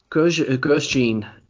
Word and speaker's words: Grosjean: We do get the return of Jimmy Johnson and Romain Grosjean. Is that how you say it? – Grosjean should be, he has a chance of being strong at that track for sure.